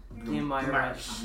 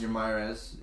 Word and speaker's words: Guimarães: I never know how to pronounce his name, but the midfielder, Bruno Guimarães. – Guimarães. That's the American way to say it.